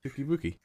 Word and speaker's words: Touki Bouki: Uh, Touki Bouki. The yeah, that's it. Touki Bouki.